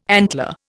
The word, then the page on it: antler: Indian